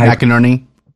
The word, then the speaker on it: McInerney: McInerney.